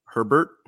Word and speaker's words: Herbert: Herbert. – Mm-hmm. – Rhymes with Sherbert.